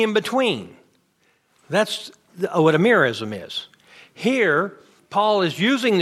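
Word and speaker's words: merism: In the ancient Near East, they had a figure of speech called a mirism. A mirism. Anybody, that won't be in your notes. It's spelled M-E-R-I-S-M, a mirism.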